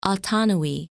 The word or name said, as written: Autonoe